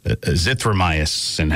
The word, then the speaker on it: azithromycin: We also touched on a drug the president had been very positive about publicly, hydroxychloroquine with the Z-Pack, the azithromycin. Hang on, let me just get Tom DiPietro on the phone. Good morning, Tom. How are you? – Morning. – Did I pronounce that right? Azithromycin? – Yes.